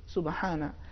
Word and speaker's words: سبحانہ: تو اس کا صحیح تلفظ سبحانہ، سبحانہ.